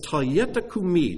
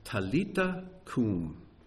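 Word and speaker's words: Talitha kumi: But he says, "Talyeta kumi." Now, that's the Aramaic pronunciation. In Hebrew, it would have been "Talita kum," which means "little girl," and the "I say to you" is not there in the Aramaic.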